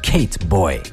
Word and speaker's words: Kate Boy: Als nächstes die Schweden Katie Boy, oder beziehungsweise, nein, Entschuldigung, das habe ich ja auch falsch gesagt, Kate Boy.